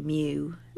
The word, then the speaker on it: Meux: Mew as in M-E-W, Mew. – No, as in M-E-U-X, but pronounced Mew.